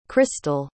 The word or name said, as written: christel